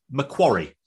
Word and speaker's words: Macquarie: Especially with regards to my favourite Australian vampire bank, which should be, apparently, Macquarie. Macquarie.